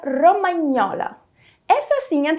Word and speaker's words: romagnola: Portanto, o GN em italiano será pronunciado como o nosso NH. Outro exemplo que eu posso te dar é o nome de outra comidinha italiana muito gostosa. Piadina romagnola.